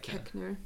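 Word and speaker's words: Koechner: And then David Kochner, if that's how you pronounce it. – I only know this because I listened to the Office Ladies podcast. – Is it Kochner? – It's Kechner. – Kechner.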